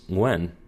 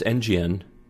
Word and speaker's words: Nguyen: Well, they had the parentheses, and the history book itself, this was our curriculum, actually said to pronounce that, you would say the letter N, and then you would say GN. And I'm like, no, it's Nguyen. And he's like, no, it's NGN.